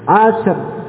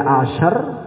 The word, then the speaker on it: ashar: Asyr, asyr, ini keliru. Atau juga kita baca ash, ya. Menghilangkan ra ini juga salah. Ya, tidak boleh ashar, juga tidak boleh ash. Nah, bagaimana membacanya? Membacanya Bapak, di sini ra-nya diikutkan tapi dengan suara lembut, pelan lirih, ya.